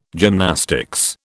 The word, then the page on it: gymnastics: British English